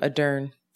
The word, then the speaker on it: Ardern: Ardern.